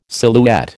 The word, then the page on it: silhouette: American English